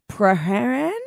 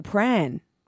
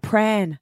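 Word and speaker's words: Prahran: Like, if you look at that word, Paran, but if you're a Melbourne local, Pran. You can even simplify it. Pran.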